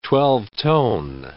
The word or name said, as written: twelve-tone